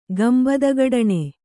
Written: ಗಂಬದಗಡಣೆ

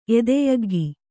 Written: ಎದೆಯಗ್ಗಿ

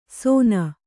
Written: ಸೋನ

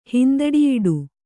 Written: ಹಿಂದಡಿಯಿಡು

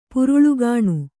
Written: ಪುರುಳ್ಗಾಣ್

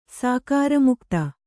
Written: ಸಾಕಾರಮುಕ್ತ

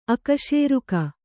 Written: ಅಕಶೇರುಕ

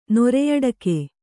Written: ನೊರೆಯಡಕೆ

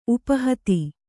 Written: ಉಪಹತಿ

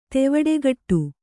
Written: ತೆವಡೆಗಟ್ಟು

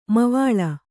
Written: ಮೈವಳಿ

